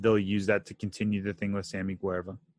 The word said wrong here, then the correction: Guevara: right? Guevara. I always say his name wrong. Guevara.